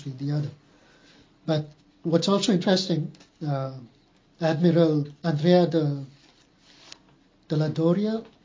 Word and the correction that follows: Andrea Doria: if I get his name right. – Andrea Doria? – Andrea Doria, yes, thank you.